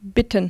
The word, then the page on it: ask: german